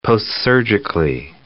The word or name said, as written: postsurgically